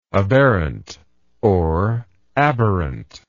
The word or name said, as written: aberrantly